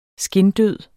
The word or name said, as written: skindød